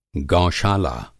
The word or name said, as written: गौशाला